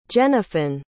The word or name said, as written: jennifyn